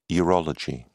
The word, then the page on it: urology: British English